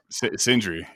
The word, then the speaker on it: Sindre: And then Matt Ingebrigtsen has come right on his heels and just continue to produce national qualifiers and, elite guys like, I don't know if I'm pronouncing it right. Sindre, is that how you say it? – Sindre, yeah. – Sindre, sorry. And it's impressive.